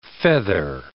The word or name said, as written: feather